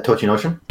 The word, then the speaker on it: Tochinoshin: Um, my my my other my other dude, my Jersey Jersey sumo man, Token Ocean, Token Ocean. How do you say his name? – T- uh, Tochinoshin.